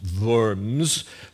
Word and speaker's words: Worms: And one of the places we went was the city that spelled Worms, pronounced Worms for the sophisticated German palate.